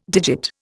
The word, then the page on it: digit: Indian